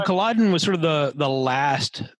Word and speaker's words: Culloden: Okay, so on that note, staying in Inverness, you mentioned going to Culloden Battlefield. – Right. – Is that how you say it? Culloden? – Culloden, yeah.